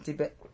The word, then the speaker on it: debut: Social networking slides into second day of trading after lackluster debut. I never could pronounce that word. – Where's it at? debut. – Debut. – Yeah. – So French.